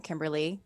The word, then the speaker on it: Kimberlie: But uh, my website is it is my first name, Kimberlie.